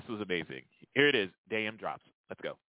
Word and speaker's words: Daym Drops: Daym Drops, Daym indeed.